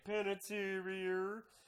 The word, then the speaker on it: Panettiere: Oh, no, it's Hayden Panettiere.